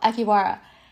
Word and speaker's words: Akihabara: That's what it's called, Akihabara. I think that's how you pronounce it.